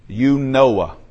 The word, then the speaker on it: Eunoia: The Greek word sounds like this. Eunoia. Eunoia. I'm just pronounce it the way it's spelled, the way it reads out.